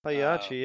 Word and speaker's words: Pagliacci: Pagliacci. Pagliacci. It's a story about a guy. – Pagliacci? – Yeah, Pagliacci. – Pagliacci, yeah.